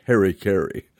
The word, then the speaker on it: harakiri: The Japanese samurai practice of committing suicide by harakiri is famous. Americans pronounce this harikiri.